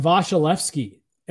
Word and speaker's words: Vasilevsky: Well, because AJ and I, or I guess I don't want to speak for, I've always said Vasilevsky And people this year started like making fun of me. I'm like, I feel like that's the correct pronunciation.